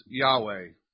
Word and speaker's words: Yahweh: And in English, it's pronounced Yahweh.